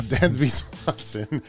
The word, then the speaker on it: Dansby Swanson: I want Swans, uh, Dansby Swans. Uh, why can't I say his name today? Uh, Dansby Swanson.